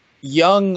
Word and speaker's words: Jung: It's spelled J-U-N-G. Jung.